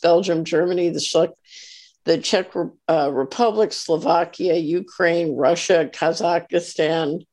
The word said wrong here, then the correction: Kazakhstan: I said that wrong. How do you pronounce Kazakhstan? – Kazakhstan, Mongolia, Canada, the U.S.